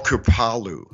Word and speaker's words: Kripalu: It's called Kripalu. – Kripalu. – Kripalu was the foundation of the Indian saint who taught me the pranayama, taught me how to breathe. And this great saint, Swami Kripalu, practiced pranayama for 10 hours a day, every single day for 30 years straight and didn't miss one day.